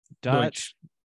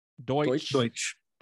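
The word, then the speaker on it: Deutch: Dutch? – Deutsch.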